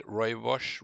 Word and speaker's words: rooibos: So um, just rooibos is that how you pronounce it? Roy Bush.